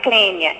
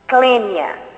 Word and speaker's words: Clênia: Não, meu nome é Clênia. É C-L-E-N-I-A. – Como pronuncia? – Clênia.